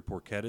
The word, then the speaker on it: porchetta: But no, around here, porchetta is pretty big, right? Am I saying that right? – Yeah. – And I, no, thank you.